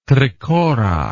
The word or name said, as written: trikora